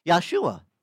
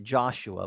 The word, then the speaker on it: Yahshua: Because he had a name, Yahshua. We pronounce it Joshua, but it's really in Hebrew, Yahshua.